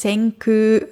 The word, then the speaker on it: Senkū: Senkū, Vorsicht, die Aussprache ist Senkū, also ein langes U. Also nicht so, wie man schreibt Senkū, also das K und U, sondern eigentlich Senkū.